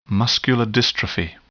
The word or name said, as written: muscular dystrophy